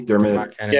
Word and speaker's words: Dermot Kennedy: Was Dermot Kennedy? – Dermot Kennedy.